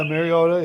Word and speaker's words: Mariota: Cousins, and then Mariotti, or how you pronounce his name, from Oregon, Mariotti. – Oh, yeah, Mariota, yep.